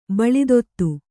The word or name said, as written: ಬಾಳ್ದುದಿ